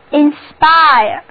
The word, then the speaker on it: inspire: 重音放在第二个音节上，字母组合 IRE 在这里读作 IRE，单词就读作 INSPIRE。